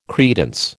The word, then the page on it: credence: American English